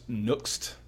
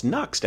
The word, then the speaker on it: Nuxt: The first one is pronounced Nuxt. – Well, it's Nuxt, actually.